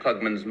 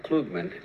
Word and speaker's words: Klugman: I pronounced your name Klugman, and you like Yeah, I always say Klugman, but the correct pronunciation is Klugman. – Klugman.